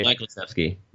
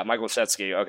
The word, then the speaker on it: Michael Schutzky: And then I also like, you know, a guy that I think will be covered, Trey Michael Schutzky, if I pronounce that anywhere near correctly. – Michael Schutzky. – Yeah, Michael Schutzky, okay.